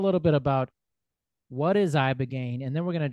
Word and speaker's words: Ibogaine: So we're going to talk about a few things, but specifically Ibogaine. Is that how you say it? – Yeah, Ibogaine.